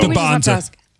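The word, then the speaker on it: Dybantsa: I say DeBansa, you say DeBonsa. – I know. Can we get one pronunciation, please? Because I've heard it both ways for 2 years. So DeBonsa. – DeBonsa. We're going DeBonsa? – Okay, he's saying DeBonsa.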